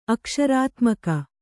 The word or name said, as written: ಅಕ್ಷರಾತ್ಮಕ